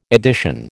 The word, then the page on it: edition: American English